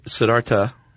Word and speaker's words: Siddhartha: Actually, Siddhartha. – Okay, so the H is silent.